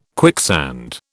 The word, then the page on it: quicksand: British English